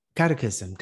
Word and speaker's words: catechism: So I had to go through, uh, I don't even know how to pronounce it, Catholicism? Cate- uh, catechism? catechism, catechism. Yeah, catecismo in Spanish.